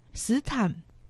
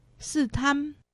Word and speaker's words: 斯坛: 那里就是斯坛，苗栗县斯坛乡，客家话四线腔发音斯坦，海陆腔发音斯坦。